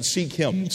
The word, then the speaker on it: Sikkim: That's how you pronounce it, Sikkim.